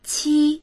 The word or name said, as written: qi1